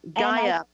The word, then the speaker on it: Gaea: The other one, G-A-E-A. – Gaia, is spelled differently.